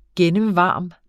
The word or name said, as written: gennemvarm